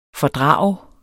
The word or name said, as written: fordrage